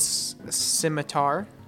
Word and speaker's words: scimitar: Okay, this Goblin's gonna run at you with its good old scimitar. Right? Is that how you pronounce it? – Yeah, yep. You did it right that time. Don't worry. Okay. – I like scimitar better, but it's scimitar.